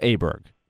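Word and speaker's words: Åberg: Now, Pontus's last name, if you really get technical in Swedish, it's something like, and the Swedish guys laugh when you even try to say it right. They say something like Auberg, Auberg, or something like that. But the Swedish guys usually say, go with the Americanized pronunciation. But now on top of that, Pontus himself, when you asked Pontus, he said he would like to be called Auberg this year.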